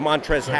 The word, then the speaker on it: Montrezl: M-O-N-T-R-E-Z-L. – He got it, folks. Ding, ding, Woo. Ding, ding. The L, I believe, is silent.